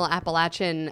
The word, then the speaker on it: Appalachian: Well, we have infused regional Appalachian inspired, if I'm saying that wrong.